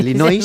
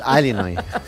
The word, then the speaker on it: Illinois: Es un estado de Estados Unidos que se pronuncia así. Es sí, Illinois, según tú. Pero es Illinois.